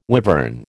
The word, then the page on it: wivern: American English